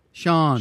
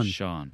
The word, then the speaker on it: Sian: Shan? Shan? S-I-A-N? – Sean. – Sean.